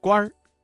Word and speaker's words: 官儿: 朝廷上当官的认为谢安能够治国平天下，这句话当中啊，当官一词的官字加了一个卷舌音日，发生了语流音变，形成了儿化音，读作官儿。